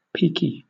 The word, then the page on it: peaky: British English